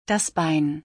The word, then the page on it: das Bein: German